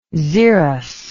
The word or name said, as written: xerus